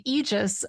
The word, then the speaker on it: aegis: So like a debate under the age, oops, yeah, I'm going to say it wrong. I have to retrain myself. A debate under the aegis of the League of Women Voters.